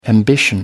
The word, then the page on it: ambition: American English